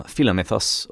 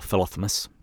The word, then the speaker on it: Philomythos: And the poem itself is actually addressed from Philomythos, or Philothemus, depending upon your pronunciation, to Misomythos, or Misothemus.